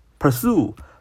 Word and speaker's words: pursue: 我们的 QQ 学习交流群是九八三九四九二五零九八三九四九二五零。我们今天来学几个单词，第一个单词叫 pursue，pursue，pursue 啊，重音在 sue 那儿啊，pursue，pursue，pursue。这个 sue 的发音呢，你可以想象这个鸡素子啊，素字叶，就那个 sue 啊。 pursue, pursue, pursue，动词 pursue, p u r s u e, p u r s u e, p u r s u e, pursue, pursue, pursue, pursue，动词什么意思呢？